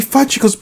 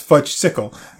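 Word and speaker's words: Fudgsicle: You promised me fudgicles, bar fudgicles. First off, it's pronounced fudge-sickle.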